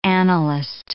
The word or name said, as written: annalist